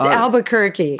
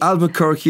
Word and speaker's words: Albuquerque: It's Albuquerque. – Albuquerque, yes.